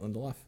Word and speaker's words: Lindelof: Lindelof.